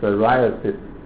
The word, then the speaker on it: psoriasis: psoriasis 那 P 不发音啊， psoriasis 这个叫做牛皮癣啊，牛皮癣不知道长什么样子，没得过不知道。